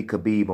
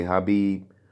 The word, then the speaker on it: Khabib: You in America, Jack. We pronounce our K's, unless we saying knife. We pronounce our K's, my boy. We don't really do the silent K's unless we talking about a knife. So you got to be Khabib on this podcast. I've been hearing a lot of people say Habib, whatever, man, whatever.